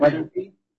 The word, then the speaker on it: Weatherby: I'm John Weatherby, J-O-H-N-W-E-A-T-H-E-R-B-Y.